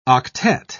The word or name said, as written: octet